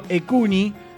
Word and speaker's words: EQNHI: Y otra cosita más, una más, porque sigo con chivos, el espacio Ecuni, ¿sí? Ecuni se escribe E-Q-N-H-I, Ecuni es el espacio cultural Nuestros Hijos,